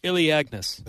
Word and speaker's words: Elaeagnus: It is spelled E-L-A-E-A-G-N-U-S. Iliagnus.